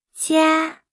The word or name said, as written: 家